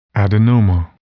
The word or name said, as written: adenoma